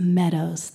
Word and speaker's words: Mmeadows: And it's not M Meadows. It's just Meadows. The first M is silent.